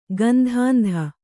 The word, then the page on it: ಗಂಧಾಂಧ: gandhāndha